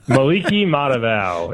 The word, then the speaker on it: Maliki Matavio: Maliki Matavio. It's actually pretty, once you get the hang of it, it's actually pretty easy. Just don't look at how it's spelled. Just sound it out and you're good.